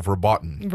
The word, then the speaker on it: verboten: Phrases such as, quote, I want to die, end quote, and quote, that test raped me, end quote, are also verboten. Is that how you say it? – Yes, are also verboten.